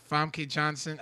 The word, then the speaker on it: Famke Janssen: Our our resident supervillain John Aponic says any X-Men movie replace Famke Janssen.